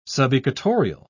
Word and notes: subequatorial: British English

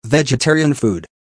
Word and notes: vegetarian food: American English

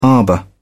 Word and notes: arbour: British English

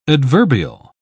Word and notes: adverbial: British English

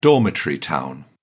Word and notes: dormitory town: British English

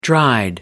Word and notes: dried: American English

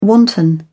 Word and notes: wanton: British English

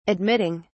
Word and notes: admitting: British English

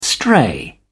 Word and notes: stray: British English

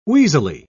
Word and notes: wheezily: British English

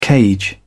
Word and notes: cage: British English

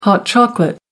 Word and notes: hot chocolate: American English

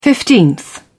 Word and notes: fifteenth: British English

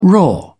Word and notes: raw: British English

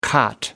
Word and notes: cot: American English

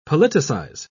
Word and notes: politicise: British English

